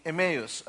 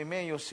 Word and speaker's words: Emmaus: So, from Emmaus, siguro Tagaytay ang labas, no? Yung iba, hindi niya nag-gets, okay? No, some pronounce it as Amos, okay?